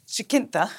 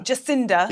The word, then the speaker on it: Jacinda: Oh, so my second has to be Jacinta. I always say her name wrong. – Jacinda.